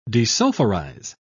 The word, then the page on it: desulfurize: British English